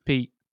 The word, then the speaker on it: Pajero: Pajero? Pajero? – Yeah.